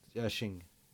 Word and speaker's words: Xing: Xing. So, crocodile. – Yeah, yeah. yeah and yeah. Xing is spelled with an X if you're typing this in looking for it, rather than a sh sound.